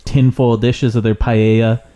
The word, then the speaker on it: paella: So people are making fucking paella and like nice Spanish dishes. – There you go. Yeah. Good pronunciation.